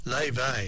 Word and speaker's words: Laufey: Spell her name Laufey, L-A-U-F-E-Y, if you're looking for it. Check on the internet, it's well worth a look. Leve is the way she pronounces it.